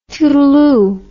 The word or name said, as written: tootleoo